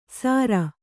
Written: ಸಾರ

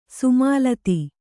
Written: ಸೋಮಲತೆ

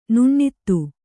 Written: ನುಣ್ಣಿತು

